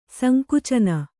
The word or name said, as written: ಸಂಕುಚನ